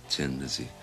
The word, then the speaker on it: Thin Lizzy: And so we made it Thin Lizzy because Dubliners say tin anyway, you know. So this was being smart, you know, the inner meaning, you know, that the pronunciation would be Tin Lizzy and the way you spelt it would be Thin Lizzy.